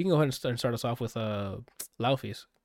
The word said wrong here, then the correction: Laufey: Lovey. Sorry. – Apparently, I saw after I heard her album, they just recommended me a video on YouTube of how to say her name. It's Icelandic.